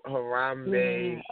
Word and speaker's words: Harambe: We got to talk about this whole Harambe, that's how you pronounce his name, situation.